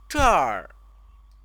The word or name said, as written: zher